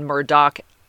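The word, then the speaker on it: Murdaugh: So Alec, A-L-I-C-K, to help me understand, that's Alec. And then Murdoch, M-U-R-D-O-C-K. So the D-A-U-G-H is actually pronounced with a K sound at the end.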